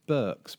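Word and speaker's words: Beukes: By Lauren, I suspect that's pronounced Burks, but it's B-E-U. – Lauren Bukes, you know her? – She did The Shining Girls.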